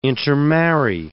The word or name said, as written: intermarry